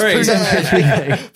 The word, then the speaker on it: Pune: It's pronounced Pune.